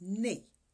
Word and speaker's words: nne: Four is pronounced nne.